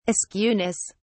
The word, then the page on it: askewness: British English